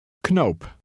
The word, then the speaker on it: knoop: In Dutch, the K is pronounced. – Knoop.